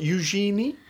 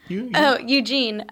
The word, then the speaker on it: Eugene: From a place, am I going to pronounce this right? Eugenie? – Eugene.